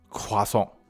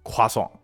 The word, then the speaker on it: croissant: 哎，那在法语的正确发音中，这个面包应该叫做夸松。哎，你看这个，你看我这法语怎么样？我再给大家说两遍啊，夸松，夸松，夸松。